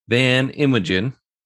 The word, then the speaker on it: Van Inwegen: Today's guest is Mr. Curt Van Inwegen.